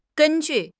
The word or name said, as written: gen1 ju4